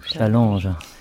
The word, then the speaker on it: Challenges: Challenge. – Mais sans S, ou avec un S. – Avec un S, mais on ne prononce pas le S.